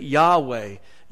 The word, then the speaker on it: Yahweh: The name of God was unpronounceable, and the Hebrew put the vowels in it, and we pronounced it Yahweh. Yahweh is the name of God, the pronounceable name of God.